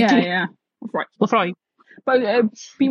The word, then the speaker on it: Laphroaig: You do pronounce the G. Laphroaig. Although I suppose when you say it, it does sound like it doesn't have the G, but yeah, Laphroaig.